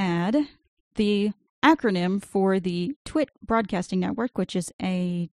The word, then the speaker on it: TWiT: They call it TWIT, but it's spelled with case changes. So it's capital T, capital W, lowercase I, capital T.